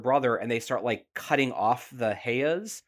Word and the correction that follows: peyot: Did I say it wrong? – Hey. – Like heya. – Heya. – Heya. – I put it in the chat. P-E-Y-O-T. Peya.